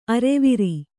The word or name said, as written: ಆರವಾರ